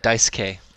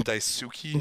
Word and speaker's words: Daisuke: What about Dice K? That's actually how you say his name.